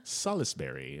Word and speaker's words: Salisbury: Salisbury is what it is. – There's an I, but you're not supposed to pronounce it.